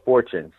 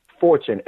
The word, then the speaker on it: Fortune: Arlando Fortune. So that's letter D as in Dwight. And Arlando is similar to, spelled like Orlando, but with an A. So D. Arlando, A-R-L-A-N-D-O, Fortune, F-O-R-T-U-N-E.